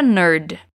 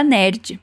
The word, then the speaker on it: nerd: And a nerd. Isso significa eu uma nerd. Olha só, em português a gente diz nerd, mas em inglês é nerd.